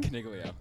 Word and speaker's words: Coniglio: Coniglio.